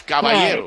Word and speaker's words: caballero: Well, it's a light A, and the two L's are pronounced like a Y. So it's caballero.